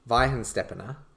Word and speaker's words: Weihenstephaner: The good thing with the Weihenstephaner, I'm probably saying it wrong every time. – Weihenstephaner.